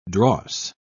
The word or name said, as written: dross